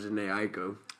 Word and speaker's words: Jhené Aiko: I understand what you're saying, but I think it's pronounced Jhené Aiko.